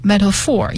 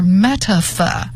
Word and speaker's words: metaphor: metaphor 这个单词有两个读音，一个是 metaphor，一个是 metaphor，英音读 metaphor，是比喻的意思。